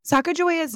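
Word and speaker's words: Sacagawea: Many believe that the Hidatsa pronunciation has a hard G sound, and instead would be pronounced Sacajawea, translating to bird woman, and this is likely how it was pronounced by the Corps of Discovery.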